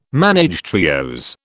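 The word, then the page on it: ménage à trios: British English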